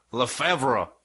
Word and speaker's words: Lefevre: Lefevre. – Yeah. – That's how you pronounce it with the French accent.